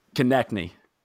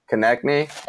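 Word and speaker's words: Konecny: Was it, I don't know how to say his name, Travis Konechny? What's his name? – Konechny. – Konechny.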